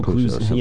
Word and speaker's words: Clouseau: So Inspector Clouseau is the right way to say it? – Clouseau, you know, like okay. – They always say it with a French accent in the movies.